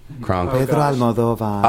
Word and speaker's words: Almodovar: Is that how you pronounce his last name? – Pedro Almodovar. – Almodovar. Yeah. That's right.